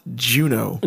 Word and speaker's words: Juneau: In an area of wilderness connecting the three city points of Juneau. – I looked it up. It's pronounced Juneau.